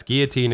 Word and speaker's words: guillotine: it's uh, it's guillotine if you do the French pronunciation, which is a French word.